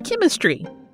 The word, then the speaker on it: chymistry: chymistry. It sounds like chemistry, but it's spelled as follows: C-H-Y-M-I-S-T-R-Y.